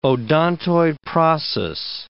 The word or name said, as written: odontoid process